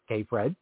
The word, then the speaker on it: KPHRED: That's K-P-H-R-E-D, pronounced so it sounds, K-Fred.